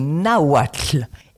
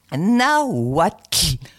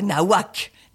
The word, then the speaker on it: Nahuatl: Maintenant, on passe au nahuatl. Écoute-moi bien. Nahuatl. Pas nahuac. Nahuatl. Alors, c'est très agréable à prononcer. T'as un petit souffle qui sort de la langue.